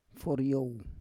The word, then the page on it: foryow: Roon